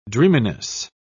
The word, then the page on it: dreaminess: British English